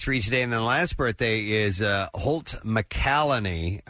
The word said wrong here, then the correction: McCallany: McCallany, that's how you say it.